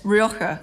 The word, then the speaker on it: Rioja: So today, we're talking about Rioja. – We are indeed, yes, Rioja, and excellent pronunciation there.